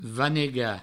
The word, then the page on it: vanegar: vanega